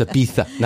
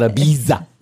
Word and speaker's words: Ibiza: It's Ibitha, not Ibiza. – It is. – It's spelled Ibiza, but it's Ibitha.